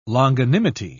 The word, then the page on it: longanimity: British English